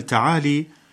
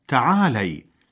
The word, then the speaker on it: تعالي: مستمعينا الكرام، يستعمل الفعل تعالي نداء للمؤنث فينطقونها بكسر اللام خطأ، والصواب تعالي بفتح اللام.